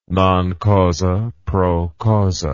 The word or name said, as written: non causa pro causa